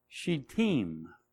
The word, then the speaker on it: Shittim: And that's the location, and it's spelled Shittim, but it's pronounced Shittim, Shittim.